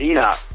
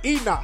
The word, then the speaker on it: Enoch: This is Enoch. – What's your name again, brother? – Enoch. – How do you spell that? – E-N-O-C-H.